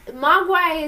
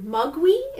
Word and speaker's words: Mogwai: Mogwai is... Based off of Cantonese. It's a Cantonese word. Mugwi is actually how you're supposed to pronounce it.